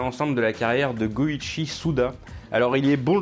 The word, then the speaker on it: Goichi: Et son pseudonyme Suda51 ça vient du fait que le 5 peut se prononcer Go en japonais et le 1 peut se prononcer Ichi, donc du coup Go Ichi 5 1 51.